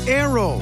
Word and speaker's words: Naoero: This program is previously called the country Nauru, N-A-U-R-U. The 11,000 residents have altered Nauru to be now Aero, N-A-O-E-R-O. They're going back to the pronunciation in their native language and losing a pronunciation used by outsiders.